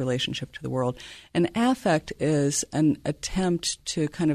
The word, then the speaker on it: affect: Affect theory is Affect. Yeah. Affect theory. Okay. – Yeah. And I always overpronounce the A, so it's clear that that we're talking about emotion.